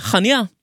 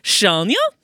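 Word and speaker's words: Chania: Eh, en av mine kjepphester er den lille byen, eller middelstore byen på Kreta som heter Hanja. Mm. Eller som samtlige turistguider sier, Shania.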